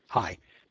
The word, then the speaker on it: Hsieh: And Diana Mertz, her last name is spelled H-S-I-E-H. I'm not quite sure how to pronounce that, so we're going to call her Hi. Diana Mertz Hi, leaving the S silent, I would imagine. H-S-I-E-H.